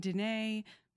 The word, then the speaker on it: Dinneh: So I am on Instagram at Caitlin Dene. Most people pronounce that incorrectly, but you can find me there. – D-I-N-N-A-H. – D-I-N-N-E-H.